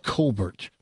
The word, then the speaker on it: Colbert: All right, we'll take a couple of calls and see what people thought about Stephen Colbert, which ought to be pronounced Colbert, by the way.